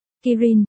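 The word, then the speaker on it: Kirin (キリン): Cậu trai dùng tiếng Trung bập bẹ và tiếng Nhật giải thích hồi lâu, có ý là hươu cao cổ trong tiếng Nhật phát âm là. Kirin, viết là, Kỳ Lân, mắt Kỳ Lâm mở to.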